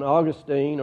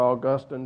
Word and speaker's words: Augustine: And we spent a particular amount of time on Augustine, or Augustine, depending how you want to pronounce it.